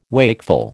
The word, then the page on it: wakeful: American English